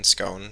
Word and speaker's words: Schoen: Unfortunate for Penn State, fortunate for Michigan, because it looked like the referee's skate hooks, Schoen, is that how you say it? S-C-H-O-E-N, Schoen, Schoen.